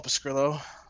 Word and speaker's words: Pescrilo: All right, well, you can find me at Paul Pescrillo on Twitter. My last name is spelled P-E-S-C-R-I-L-O.